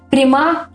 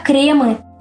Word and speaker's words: кремы: Мы привыкли говорить «крема», «о кремах» и так далее. Однако, по данным словарей, правильно именно «кремы», и ударение в этом слове всегда должно ставиться на первый слог.